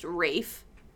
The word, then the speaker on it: Ralph: Well, his name, first of all, is spelled like Ralph, but it's pronounced Rafe, which is wild.